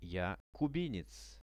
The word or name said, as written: ja kubinec